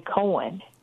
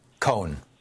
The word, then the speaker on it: Cohn: Then finally McCarthy and Ray, you said Ray Kahn or Ray Cohen? – Cohn. One syllable. – Cohen. Okay, C-O-H-N. Anyway, right.